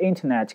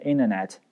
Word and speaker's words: internet: OK, get into the internet, 那变成一个句子就是 she's really getting into the internet, she's really getting into the internet, 但这个 internet 可以变成 internet, 那个 t 可以不发音，这都 OK。